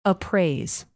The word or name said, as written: appraise